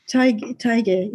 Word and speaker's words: Teige: Is that how you pronounce the name? Teige? – I think it is, yeah. Teige, yes.